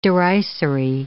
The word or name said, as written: derisory